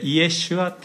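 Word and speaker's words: イエ シ ュア (ישוע): これはヘブライ語でイエシュアっていう発音します。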